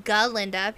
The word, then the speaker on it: Galinda: So, we have a little bit more information on Galinda at this particular point in time, and it is Galinda because the way it's spelled is G-A-L-I-N-D-A. Dr. Dillamon can't pronounce it, so he pronounces it just Galinda with no A in between the G and the L.